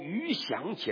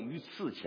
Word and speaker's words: 鱼翔: 我们读那个毛泽东诗词说：“ 鹰击长空，鱼翔千里。” 鱼在海里像飞翔一样，他读成鱼刺千里。